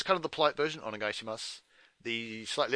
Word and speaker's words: Onegaishimasu: Onegaishimasu.